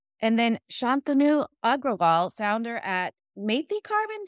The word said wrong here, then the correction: Mati Carbon: Did I say it right? – Mati. – Mati Carbon. Yes.